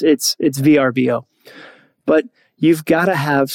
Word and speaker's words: Vrbo: Although VRBO, they came out and were like, no, our name is pronounced Vrbo. Dude, no one calls you Vrbo.